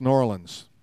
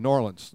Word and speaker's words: New Orleans: And if you, you know people who've lived there or are born there, the way they say it, if you say it with two words, you've never been there. It's not New Orleans. Those are pure rookie moves. You have to say it like this New Orleans.